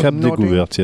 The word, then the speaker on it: Cap Découverte: the very memorable time trial from Gaillac to Cap Découverte. Is that the correct pronunciation? – Cap Découverte, yeah.